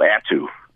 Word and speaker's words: Attu: That's Attu.